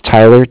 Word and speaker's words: Tyler: My name is Tyler, T-Y-L-E-R. And I'm calling with Cardiff. It's spelled C-A-R-D-I-F-F, as in Frank.